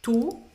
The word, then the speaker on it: tu: E aqui, qu'est-ce tu, tu ou você, né? – Tu, tu. – Faz a boca do U e fala aí para pronunciar o tu.